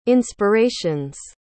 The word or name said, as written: inspiration's